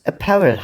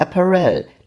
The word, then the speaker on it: Apparel: Wir wollten nochmal hinzufügen, dass es Apparel heißt, nicht Apparel, lieber Hauke. Die Betonung liegt auf dem A. Antifa, Alpaka, Apparel.